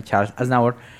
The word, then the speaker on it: Charles Aznavour: Acabé de revisar cómo se pronunciaba porque no quería equivocarme, Charles Aznavour.